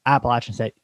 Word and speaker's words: Appalachian: App Appalachian State, not Appalachian State, Appalachian State. You have Appalachian State Mountaineers plus 5.5 against Coastal. – Yes, I do. And again, Appalachian, not Appalachian.